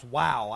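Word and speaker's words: vav: Now, we've covered, we didn't get to finish covering, I called it vow. It's not vow, it's pronounced wow. I had to get that by going back and doing some studying. But it's pronounced wow. It's spelt V-A-U in English, but it's pronounced with a W.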